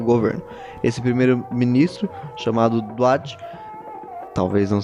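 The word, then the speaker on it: Mohammed Daoud Khan: A pronúncia correta é Mohammed Dawood Khan, que na verdade ele era primo do rei.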